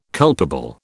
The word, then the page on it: culpable: British English